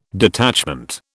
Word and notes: detachment: British English

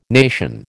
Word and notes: nation: American English

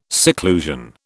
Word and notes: seclusion: British English

